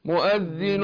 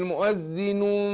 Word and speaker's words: مؤذن: شوف صوت الميم، لأن في كتير بنطقها غلط يفتح الشفتين فيقول مؤذن فين الميم؟ لكن مؤذن مؤذن لأن الميم بتخرج بانطباق الشفتين، ما أقول أم.